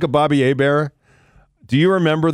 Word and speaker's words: Hebert: You know, it's Herbert, H-E-R-B-E-R-H-E-B-E-R-T. Nothing to pronounce. Try and say it. Hebert. Okay, take a shot. – Hebert.